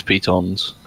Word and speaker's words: pitons: Yeah, no, it's a French word. It's pitons.